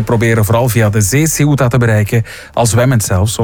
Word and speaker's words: Ceuta: Ceuta.